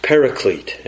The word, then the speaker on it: paraclete: paraclete, and it is a broad term that can be translated in different ways to try to embrace all that it means.